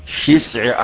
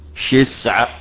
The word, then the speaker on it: شسع: كما أنطقها لك، إذا انقطع شسع أحدكم، شين في أول الكلمة مكسورة ثم سين ثم عين، شسع أحدكم.